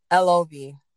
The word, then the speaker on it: Elohvi: How do you pronounce the name of your company? – Elohvi.